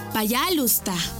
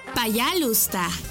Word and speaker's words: Пожалуйста: Y se dice Пожалуйста. ¿Cómo? Пожалуйста. Una vez más. Пожалуйста. Y se escribe Пожалуйста. P-A-Y-A-L-U-S-T-A.